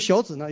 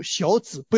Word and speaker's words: 小子: 这个小子呢要读小子，不要读成小子啊，这个小子是对别人，你这个臭小子对吧？